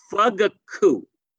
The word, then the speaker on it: Fugaku: It's called fugaku. Okay. F-U-G-A-K-U.